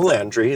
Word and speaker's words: Landry: It's your boy, self-love coach and you do you activist, here with Sarah Nicole Landry.